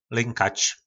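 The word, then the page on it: Lynkač: nářečí Po našimu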